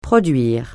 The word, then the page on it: produire: francais